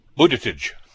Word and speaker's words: Buttigieg: Then Mike Pence, Alexandria Ocasio-Cortez, and I always get his name wrong, Pete Buttigieg. – Buttigieg. – Buttigieg, there we go.